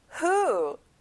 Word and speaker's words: who: Who? 여러분 여기는 W 발음이 안 나요.